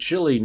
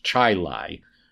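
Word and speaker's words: Chili: And one of them, I said, Chile, New York. Well, it's not Chile, New York. It's Chi-Lai, New York.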